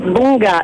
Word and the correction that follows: bunda: it's bunda.